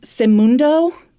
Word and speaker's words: Seimundo: Investigators learned that Imette was born in Boston to, I believe this is pronounced Semundo, that's her dad's name, S-E-I-M-U-N-D-O, Guillen, and Maureen St. Hilaire, so that's her mother.